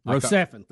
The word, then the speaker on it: Rocephin: Rocephin.